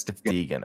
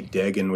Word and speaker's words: Deegan: Did you tell me that it is Deegan or Dagan? – Yeah, that's right. – It's Deegan. Okay, I'm glad I remembered correctly. – Because it's not pronounced as it's written, which always leads to people calling me Dagan, which is probably, you know, before Ellis Island was the correct way to say it.